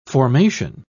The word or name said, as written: formation